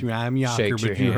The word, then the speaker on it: Jaker: Some people call me Jaker just because they don't know no better and because it's kind of spelt that way, but it's pronounced Yawker.